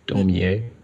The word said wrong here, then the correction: Dormer: is that how you pronounce it? – I don't know. – Oh. I think it's actually Dormer.